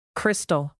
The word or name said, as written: crystal